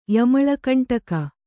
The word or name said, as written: ಯಮಳಕಂಟಕ